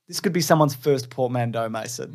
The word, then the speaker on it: portmanteau: Portmanteau.